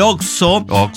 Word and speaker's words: Oxxo: Claro, algunos marcan más las X, hacen el Oxxo. Otros le dicen, ah, voy al oso, enseguida regreso.